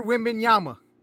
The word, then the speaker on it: Wembanyama: I thought it was an N and not an M. – Wembanyama. Yana. No, it's Victor Wembanyama. Yama, oh. with a M.